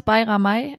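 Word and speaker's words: Bayramaj: Alushi, ehemals Bayramay. – Super, ja. Gut ausgesprochen.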